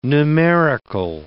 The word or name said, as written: numerical